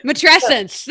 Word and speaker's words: matrescence: You've kind of talked about it a little bit, but tell us about, I'm going to say it wrong, and I know it rhymes with adolescence. Matrescence.